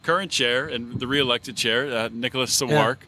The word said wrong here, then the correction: Sarwark: Um, really, it's Sarwark. He's in Sarwark.